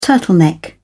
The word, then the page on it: turtleneck: British English